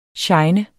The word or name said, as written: shine